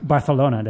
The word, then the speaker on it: Barcelona: Barcelona, did I say that right? – Exactly.